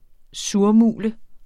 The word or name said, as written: surmule